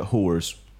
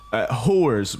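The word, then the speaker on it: Hoare's: Whores, but it's spelled... – Whores? Yeah. Interesting. Okay. – It is spelled HORE'S.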